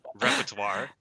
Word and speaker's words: repertoire: Repertoire. I'm saying it wrong. Worse every time. repertoire. There we go. That was good.